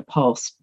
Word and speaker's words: POLST: The title of my most popular requested presentation is called Making Advanced Care Planning Easier with a Georgia Pulse. That's spelled P-O-L-S-T, and it stands for Physician Order for Life-Sustaining Treatment.